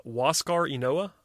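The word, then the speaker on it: Huascar Ynoa: And I'm going to try to pronounce his name right now. It's Waskar Enoa. That's what I'm going with. That's what the pronunciation guide says.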